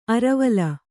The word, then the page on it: ಅರೆವಲ್ಲು: arevallu